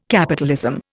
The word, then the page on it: capitalism: Indian